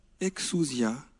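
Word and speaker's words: exousia (ἐξουσία): Le pouvoir, en grec, ça se prononce « exousia », et ça veut dire un pouvoir délégué.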